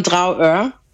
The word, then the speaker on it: Dragør: Pisze się Dragør, wymawia się Trouwø.